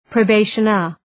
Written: probationer

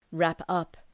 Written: wrap up

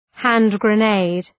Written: hand-grenade

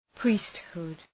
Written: priesthood

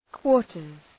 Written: quarters

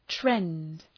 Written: trend

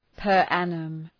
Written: per annum

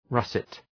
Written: russet

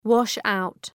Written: wash-out

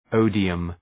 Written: odium

